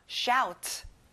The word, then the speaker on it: shout: OU는 아우 소리 나요. shout. Excellent.